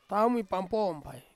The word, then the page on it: taumi pampom pai: Ansus